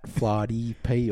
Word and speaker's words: Flaherty_P: At Flardy P or something like that, isn't it? – Oh, Flardy _P.